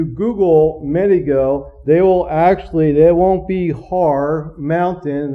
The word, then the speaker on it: Har: That's the Hebrew word for mountain is you pronounce it Har with the H, but they don't, in Hebrew is R, but you pronounce it with a hard breathing.